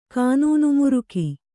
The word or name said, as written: ಕಾನೂನುಮುರುಕ